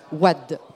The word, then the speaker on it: Wade: Est-ce que je prononce bien Wade? – Wade.